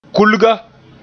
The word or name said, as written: kulga